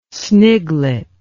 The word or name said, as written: sniglet